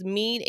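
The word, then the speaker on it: mien: So everyone asks me, is mean a real word? It actually is. It's spelled with one I, M-I-E-N.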